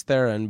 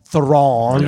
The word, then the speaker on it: Theron: Charlize, and I always call her Charlize Theron, but, you know, Adam corrected me, it's Thrawn.